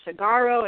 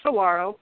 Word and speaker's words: saguaro: Like some, if you hadn't, some, I hear people saying saguaro, and because it has the G, but it's pronounced saguaro.